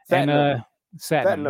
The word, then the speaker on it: Satnam: And Big Satnam Singh, or however you pronounce that shit. – Satnam. – There you go.